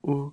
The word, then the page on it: u: Lwaitel